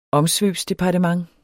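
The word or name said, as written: OMSVØBSDEPARTEMENT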